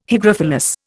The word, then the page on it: hygrophilous: Indian